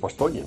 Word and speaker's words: Bastogne: Ya sé que no voy a conseguir nada, pero con decir Bastoña, porque en francés es Bastoña, no hay otra traducción.